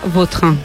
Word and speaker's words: Vautrin: La original francesa, Vautrin.